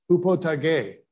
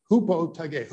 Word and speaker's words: hypotage: The word submission, the New Testament, spelled, I should put, it's spelled H-Y-P-O-T-A-G-E in English, but that's not how it's pronounced. It's pronounced hupotage, hupotage.